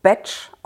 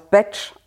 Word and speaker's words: Bach: Genauer gesagt, der Name wird eigentlich Batch ausgesprochen, ach, wird aber wie das Worte, deutsche Wort Bach geschrieben. Deswegen sagt man in Deutschland Bach, weil er bzw. seine Familie sind Waliser gewesen und da wird halt Bach, also diese 4 Buchstaben, als Batch ausgesprochen.